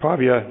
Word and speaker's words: Pavia: is that how you pronounce it? – Pavia, in Italy, under the mentorship of Professor Antonio Toroni.